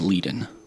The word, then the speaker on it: Liadan: So, N-E-A is actually... – Go phonetically. – E-I-T-H-N-E. It's an older version of Enya, actually. And then Leiden is actually L-I-A-D-A-N... with an accent over the I. – This is Enye, and her sister, who seems to be causing some problem, is Leiden.